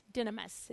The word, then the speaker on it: dynamis: The root word in the Greek is dynamis, dynamis, dynamis, it's pronounced.